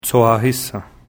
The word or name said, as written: Tsohahisen